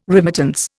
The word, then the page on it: remittance: Indian